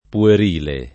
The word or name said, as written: puerile